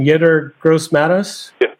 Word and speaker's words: Yetur Gross-Matos: Yetur Gross-Matos.